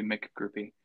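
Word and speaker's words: McGroupie: you're a, and I'm going to say it say it wrong the first time, a Droopy groupie. You've, you've corrected me. It's Droopy Mick groupie. – Yeah, you got to make sure you got to get that Mick in there or else, you know, it's, okay.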